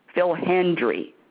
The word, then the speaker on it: Hendrie: you are going to go crazy over Phil Hendry. His name is Phil, and his last name is spelled Hendrie. Phil Hendry.